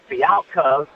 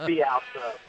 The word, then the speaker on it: Fialkov: Just to correct you, my name is Fialkov, because my wife's sitting next to me, and she doesn't like when it's mispronounced. So it's Fialkov.